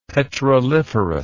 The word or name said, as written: petroliferous